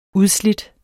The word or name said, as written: udslidt